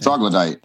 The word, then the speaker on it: troglodyte: How do you say troglodyte? Troglodyte? – Troglodyte. – Thank troglodyte. – You. – Troglodyte, yeah. Yeah.